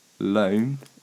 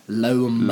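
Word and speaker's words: Lomé: Loam is the capital of... Do you know this one? – No. – Okay. – Can you just spell that? – L-O-M-E. – Loam. – Loam.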